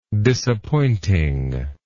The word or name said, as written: disappointingness